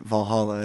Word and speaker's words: Valhalla: And I love how you say the name right, Valhalla. That's really good. A lot of people have a hard time saying that.